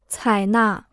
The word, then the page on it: 采纳: cǎi nà